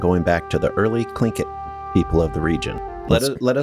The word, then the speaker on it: Tlingit: I'm like, how do you say this? – And she said Tlingit? – Tlingit.